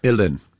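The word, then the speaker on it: uilleann: Illin. – I'm sorry, what is it? – It sounds so much easier than that's Irish, the Gaelic, you know? – Yeah. – It's illin. Like chillin, illin.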